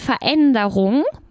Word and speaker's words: Veränderung: Otra palabra que es die Veränderung, ¿vale? Se pronuncia empezar como con una F así bien pronunciada.